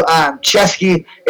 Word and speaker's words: Ceschi: So you want to fucking do Chesky. It's spelled C-E-S-C-H-I.